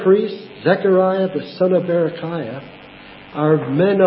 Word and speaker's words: Berechiah: Berechiah, that's how you say it, Yahweh blesses.